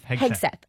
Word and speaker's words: Hegseth: Is that how you pronounce it? – Hegseth. – Okay. Yeah.